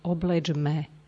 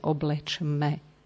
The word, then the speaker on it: oblečme: Pokiaľ ide o výslovnosť, máme tu slovo, to je už asi v druhej tretine tohoto čítania, oblečme. Pozor, aby nenastalo, aby sme nepočuli vyslovenie oblečme. To je neprirodzené pre Slovenčinu, pretože my máme veľmi veľa spodobovaní.